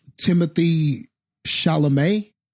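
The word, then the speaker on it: Timothée Chalamet: I thought that Timothy Chalamet, I'm just guessing that that's how you pronounce that.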